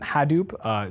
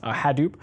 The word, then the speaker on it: Hadoop: We have community solutions like Hadoop. Is that how it's pronounced? – Yes. – Hadoop.